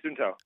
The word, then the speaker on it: Suunto: This wearable fitness tracker from, how is it pronounced, Suunto? – Yeah, Suunto.